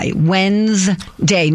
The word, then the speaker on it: Wednesday: So the question is about the D. So you woke up. – All we need is W-E-N-S-D-A-Y. Wednesday, not Wednesday. Why is Wednesday spelled like that?